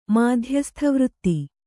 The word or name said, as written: ಮಾಧ್ಯಸ್ಥವೃತ್ತಿ